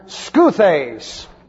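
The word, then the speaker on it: Skuthes (Σκύθης): Now, barbaros and skuthes. This is the way it looks in the Greek. S-K-U-T-H-E-S.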